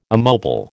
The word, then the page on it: immobile: American English